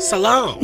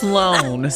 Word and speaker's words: Sloan: You said Salon? – Salon. – It's Sloan. – Sloan. – It's there's no B. There's okay. – No, you're adding an extra syllable. It's Sloan.